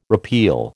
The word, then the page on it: repeal: American English